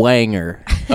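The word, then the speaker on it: Berwanger: B-U-R. – It's spelled it's Burr. – B-E-R. – Oh, B-E-R. See, I wouldn't know that. – And then W. – Wanger. – Okay. – It's almost like Wrangler.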